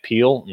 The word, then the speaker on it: Peale: You can go to my website, Matt Peale, last name is spelled P-E-A-L-E.